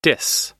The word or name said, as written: dis